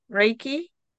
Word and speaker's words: Reiki: Am I even pronouncing this correctly? Reiki? – Yes, you are.